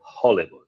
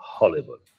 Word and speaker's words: Hollywood: Pero claro, estas palabras con H, en inglés, esa pronunciación J no es tan fuerte, sino que es una H, ¿no? Como podría ser Hollywood, ¿no? Hollywood.